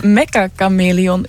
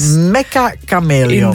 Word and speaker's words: Mecha Chameleon: Ik had het ook moeilijk bij de uitspraak, ja. Maar een nog jongere collega van mij heeft mij laten weten. Ja, dat bestaat. Dat het Mecha Chameleon is. – Mecha Chameleon. – Inderdaad, zo zou je het uitspreken.